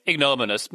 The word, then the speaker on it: ignominious: But I also think, you pronounce it, by the way, it's ignominious.